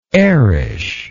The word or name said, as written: airish